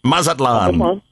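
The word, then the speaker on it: Mazatlán: I'm not sure how to pronounce it. – Mazatlan. Yes.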